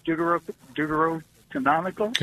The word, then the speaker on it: Deuterocanonical: My question is when, because I know the Deuterocanonical.